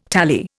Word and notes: tally: Indian

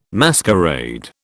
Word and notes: masquerade: British English

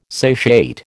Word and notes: satiate: American English

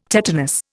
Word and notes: tetanus: Indian

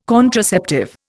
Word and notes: contraceptive: Indian

